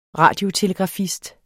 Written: radiotelegrafist

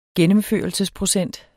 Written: gennemførelsesprocent

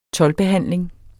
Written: toldbehandling

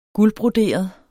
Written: guldbroderet

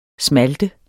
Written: smalte

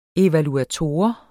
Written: evaluator

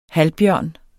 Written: halvbjørn

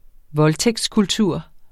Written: voldtægtskultur

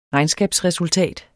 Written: regnskabsresultat

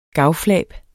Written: gavflab

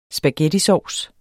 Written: spaghettisovs

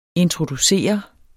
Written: introducere

